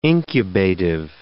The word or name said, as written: incubative